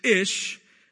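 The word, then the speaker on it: ish (איש): And in Hebrew, the word for man is ish, and the word for woman is ish-ah. Probably pronounced ish-ah, but I just give a little flair there.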